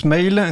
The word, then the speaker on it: Maol: Avec un groupe qui s'appelle Mail, en tout cas ça s'écrit Maol, mais ça se prononce Mail.